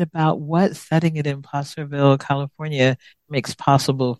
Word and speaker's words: Placerville: So it's set in, yeah, I was saying Placerville, which is not how it's said. Is could you say it again for me? – Placerville.